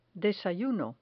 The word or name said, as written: desayuno